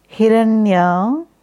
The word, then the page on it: Hiranya: Sanskrit